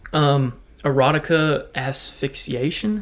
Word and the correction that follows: autoerotic asphyxiation: is that right? Is that how you say it? Autoerotic asphyxiation.